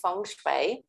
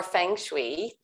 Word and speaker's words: Feng Shui: Well, you pronounce it Feng Shui or Feng Shui.